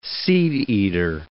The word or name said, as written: seedeater